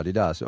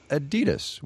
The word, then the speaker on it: Adidas: You've been saying Adidas, as in Adidasler, Adidas. Over here we say Adidas. Which one's right? – Oh, definitely the European pronunciation, and it's really strange.